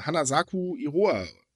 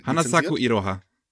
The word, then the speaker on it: Hanasaku Iroha: Ähm, Anime House hat Han, äh, Hanasaku Iroha, spricht man auch nicht so aus. Äh, Hanasaku Iroha. – Ja, oder so.